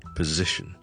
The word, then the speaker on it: position: position, position, position 是一个 Z 音来的，中间的 S, OK, Z, 但后面有个 SH, SH, OK, 我们练了这个字怎么读先，听着做一件事， Z 音 Z, 跟着后面好像 fish 那个 ish, 跟我读一次 fish, fish, fish, 我们不要个 F 变成 ish, ish, ish, OK, 跟着帮我前面加个 Z 音，zish zish zish，咁咧就系 position position position position position。